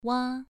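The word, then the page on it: wa: tone 2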